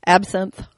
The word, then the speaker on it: Absinthe: I think I'm pronouncing it right. Absinthe. – Yes. Absinthe.